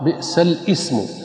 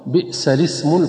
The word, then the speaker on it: بئس الاسم: ومن الكلمات التي تنطق خطأ كلمة بئس الاسم الفسوق بعد الإيمان، فينطقها البعض بئس الاسم، حيث ينطق بالهمز. والصواب أن تنطق اللام مكسورة بئس الاسم الفسوق هكذا مع تحريك اللام، بئس الاسم الفسوق، تحريك اللام بالكسر.